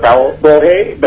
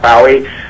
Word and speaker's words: Bohe: Yes, my name is Robert Bohe, but it's actually pronounced Bowie, spelled Bohe.